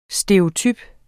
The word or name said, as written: stereotyp